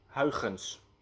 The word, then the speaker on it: Huygens: Huygens. I've probably said that wrong now. – Christian Huygens. So there you have it. Another Dutch name explained.